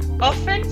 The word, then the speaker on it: often: Often se escribe often, pero la T no suena, ¿vale?